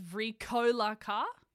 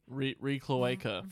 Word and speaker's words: Vricolaca: The second correction was the pronunciation is Vricolaca. The stress is on the co. – Vricloaca.